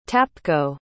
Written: tapko

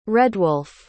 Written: redwulf

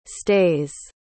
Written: stays

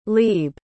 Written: leib

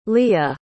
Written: leah

